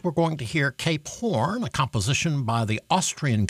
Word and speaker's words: Hoorn: They were from Horn, a city in the Netherlands, and that's spelled H-O-O-R-N in Dutch, but is pronounced Horn, hence Cape Horn.